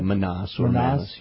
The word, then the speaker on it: Manas: Yeah, Manas.